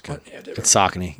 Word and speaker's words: Saucony: You said it right too. It's Saucony. Yeah. – I said Saucony.